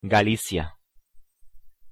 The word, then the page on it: galicia: Spanish